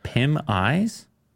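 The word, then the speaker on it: PimEyes: Is that how you pronounce that? Pim Eyes? – Yeah.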